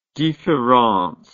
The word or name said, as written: differance